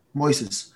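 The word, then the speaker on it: Moisés: Moises.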